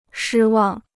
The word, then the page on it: 失望: shī wàng